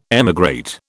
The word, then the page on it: emigrate: British English